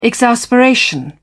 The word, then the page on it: exasperation: British English